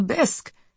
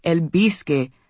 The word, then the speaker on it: bisque: I kept trying to get her to order something else, but she kept asking for more bowls of the bisque, Charlize says as she goes through a stack of paper menus, and I kept saying, Madrina, it's bisque, not el bisque. The E is silent.